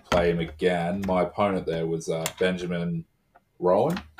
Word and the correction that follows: Ruffin: Okay. – I think that's how you say his name, from the ITA Barracudas. – Oh, Ben Ruffin. – Ruffin, yeah, that's it.